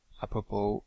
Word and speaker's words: apropos: One interesting utility for dealing with main pages is the apropos comment, apropos, which is spelled A-P-R-O-P-O-S, apropos in French, will search the main pages in the name of the command, but also on their description.